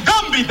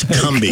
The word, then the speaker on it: Cumby: I'm Gumby, damn it! – No, it's Cumby. Cumby. It's spelled with a C. Nice. Yours is with a G.